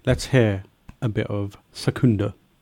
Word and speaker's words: Secunda: Secunda. – Yeah, Secunda.